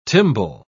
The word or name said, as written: timbale